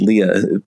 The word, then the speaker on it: Lija: She spells her name L-I-J-A, but I believe it's Leah pronouncing it.